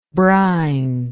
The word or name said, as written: brinishness